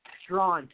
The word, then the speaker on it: Straughan: Karen, is it Strahan? Is that how I pronounce it? – Strawn. – Karen Strawn.